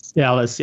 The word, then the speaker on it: Ellis: I don't think I could pronounce her name really well, but Ajanun Elise or Ellis, is that how you pronounce her name? I believe so. Ellis, yes.